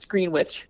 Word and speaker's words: Greenwich: I assume it's pronounced Greenwich. It's Greenwich.